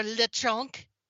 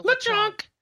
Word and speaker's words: Lechonk: Got to say it right. – Lechon, without the K. – No, Lechonk. – Is a Spanish dish of, like, young pig. – Lechonk. – So it's like Lechon plus chonk. Lechonk.